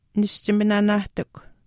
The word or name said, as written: nischiminaanaahtikw